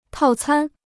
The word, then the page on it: 套餐: tào cān